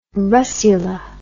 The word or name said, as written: russula